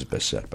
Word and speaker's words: Bisset: Pronounces Bisset, by the way.